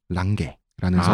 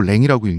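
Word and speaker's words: Lange: 랭은 원래 북유럽 성이에요. 랑게라는 성을 랑게. – 프랑스어로 랭이라고 읽는데 금발에다가 푸른 눈이었어요.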